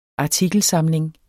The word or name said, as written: artikelsamling